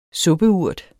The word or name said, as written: suppeurt